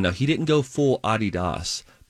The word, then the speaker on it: Adidas: The appropriate way to pronounce it. We learned that a few years ago. It's a German family, Adidas. That's how you pronounce the name. We Americans decided, nah, we'll change it and we'll make it Adidas.